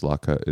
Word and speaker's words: Latke: I'm really, as I've told you at your, when I saw you at your lot key gathering, I'm really, really scared of giving birth. – I just have to stop you and say the way you pronounce Latka is psychotic and completely unacceptable.